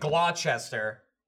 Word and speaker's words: Gloucester: There's also one that looks like Gloucester, and it's Gloucester. Gloucester. – Gloucester. Yeah. – Gloucester.